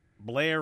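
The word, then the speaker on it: Belair: Beth says, in case you're wondering how Blair is spelled, it's B-E-L-A-I-R. I was giving the Baltimore pronunciation.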